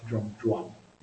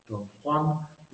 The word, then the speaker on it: Don Juan: We are going to read book one, most of book one of Don Juan, which you have to pronounce Don Juan. You cannot pronounce it Don Juan, because if you pronounce it Don Juan, then the rhyme doesn't work.